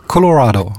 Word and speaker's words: Colorado: Colorado.